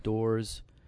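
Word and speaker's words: Doré: And it's a replica or a take on Gustave Dore's Ezekiel Valley of Dry Bones. – I believe it's pronounced Dore.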